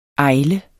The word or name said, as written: AJLEN